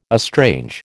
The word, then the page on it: estrange: American English